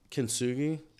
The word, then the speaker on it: kintsugi: So John gave me kintsugi. Am I saying it right? – Yes, definitely.